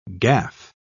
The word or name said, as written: gaff